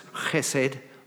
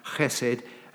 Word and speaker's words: chesed: But as I read about it, I learned that there's a particular word in Hebrew, and I think it's pronounced chesed, like that, chesed, and it's translated in the Hebrew Bible as loving kindness.